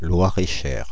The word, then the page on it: Loir-et-Cher: France (Île-de-France); [lwa.ʁ_̌e.ʃɛʁ]